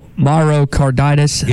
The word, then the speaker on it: myocarditis: Chris in Fayetteville, is it myocarditis? – Yeah.